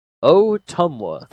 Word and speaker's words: Ottumwa: Ottumwa? Ottumwa? It starts with OTT, so it's definitely Otters related. It's near the Iowa-Kansas City border. Ottumwa. Ottumwa. That's how you say it.